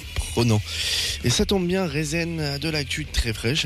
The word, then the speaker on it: Rezn: Rezen. R-E-Z-N, ça se prononce comme ça.